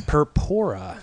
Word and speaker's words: Purpura: Purpura. – Purpura. And how do you spell it if you don't? – P-U-R-P-U-R-A.